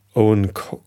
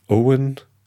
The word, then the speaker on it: Eoin: Und ähm, ich glaube, da hat mir das jemand erzählt, von Owen Cofer, ein irischer Schriftsteller. Den schreibt man E-O-I-N, Kolfer. Aber ich glaube, man spricht ihn Owen Cofer oder Kolfer, Das weiß ich nicht so genau.